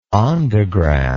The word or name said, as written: ondograph